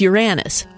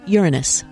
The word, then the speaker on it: Uranus: Well, according to Schoolhouse Rock, it's Uranus. But I just looked it up on YouTube, and Nat Geo said Uranus.